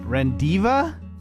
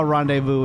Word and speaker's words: rendezvous: R-E-N-D-E-Z-V-O-U-S. R-E-N-D-E-Z-V-O-U-S. – Rendiva? Divu? Rendezvous.